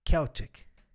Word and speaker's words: Celtic: O irlandês pronuncia Celtic. Caraca. Porque o C em gaélico é K.